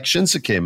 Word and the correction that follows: Shinsuke: man, Shinsuke, sorry for the extra syllable there.